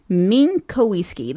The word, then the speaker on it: Minkowski: So furthermore, Ido Minkowski, how would you even pronounce that? Minkowski. This is the spokesperson for Black Cube.